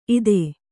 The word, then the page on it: ಇತ್ತು: kannada